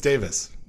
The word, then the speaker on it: Davies: I mean, it's spelled Davies, but is it pronounced Davis? I don't remember. – They all say it. They all say it's Davis.